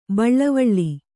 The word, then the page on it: ಬಳ್ವಳ: baḷvaḷa; kannada